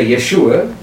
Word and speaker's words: Yeshua: Either Yeshua or Yehoshua, either one, if you want to get the Hebrew pronunciation right.